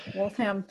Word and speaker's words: Waltham: There, I butchered it again. – How was he from? – Waltham. – Nathaniel Banks? Oh my God, yes, you got it. – Waltham.